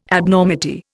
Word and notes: Abnormity: Indian

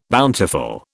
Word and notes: bountiful: British English

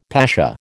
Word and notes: pasha: American English

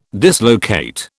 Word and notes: dislocate: British English

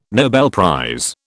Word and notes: Nobel prize: British English

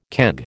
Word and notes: keg: American English

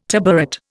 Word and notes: tabouret: Indian

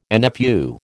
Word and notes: NFU: American English

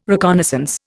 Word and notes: reconnaissance: Indian

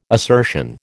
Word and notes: assertion: American English

